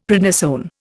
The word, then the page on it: prednisone: Indian